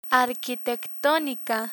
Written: arquitectonica

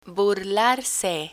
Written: burlarse

